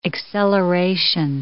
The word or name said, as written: acceleration